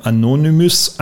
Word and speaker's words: Anonymous: Ja, wir haben ja, ich habe ja im Entree schon die Hackergruppe Anonymous angesprochen, so wird sie richtig ausgesprochen.